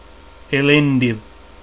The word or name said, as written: Elendil